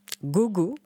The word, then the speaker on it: Gogo: Alors, Gogo, je ne suis pas sûre que ça se prononce comme ça, mais là, je ne l'ai jamais entendu, à l'ouest du pays. – Go, c'est voilà. Comme ça que ça se dit.